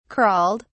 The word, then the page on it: crawled: British English